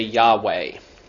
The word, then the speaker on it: Yahweh: We insert the vowels in there and we say Yahweh. And that's probably the closest pronunciation that we can get to that.